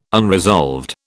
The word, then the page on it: unresolved: British English